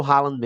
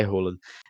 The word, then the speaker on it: Haaland: E eu falo Haaland mesmo, porque até ele mesmo já disse que não tem problema, então é Haaland. mas enfim, eu sei que a forma correta de pronunciar é Roland.